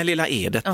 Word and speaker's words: Lilla Edet: Och i närheten av Lilla Edet, sa du? – Ja. Nej, Lilla Edet. Oh. Vi får lära oss det här nu, uttalare.